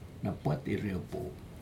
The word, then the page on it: napat irecpo: Aneityum